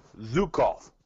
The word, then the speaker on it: Zhukov: The Hart Foundation, Bret Hart and Jim Neidhart, versus the Bolsheviks, which consists of Nikolai Volkov and Boris Shukov. – All right, I got to stop you there. Zhukov. – Thank you. – No, seriously, Zhukov. That's how you say it.